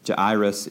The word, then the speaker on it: Jairus: And I've heard this guy's name pronounced every which way imaginable. Jairus is probably the closest. You might have heard Jairus or Jairus even, but Jairus is pretty close.